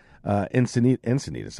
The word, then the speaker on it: Encinitas: Encinitas, I believe it's pronounced.